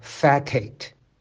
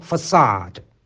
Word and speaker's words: facade: 那第一个单词呢是法文借用过来的词汇啊，它不读作 facade，而是读作 facade。